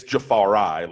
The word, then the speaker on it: Jafari: And everybody said that Jafari Harvey, because his aunt sent, like, anybody associated with Miami media an email and said, no, you're saying his name wrong. It's Jafari, like Rastafari.